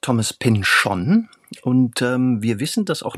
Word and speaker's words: Pynchon: Aber wir wissen nun immerhin, dass er Thomas Pynchon sagt. – Das heißt, in dieser Serie hat man ihn so ausgesprochen. Ja, es hat sich aber anscheinend, würde ich sagen, 20 Jahre ist es jetzt her, nicht groß rumgesprochen.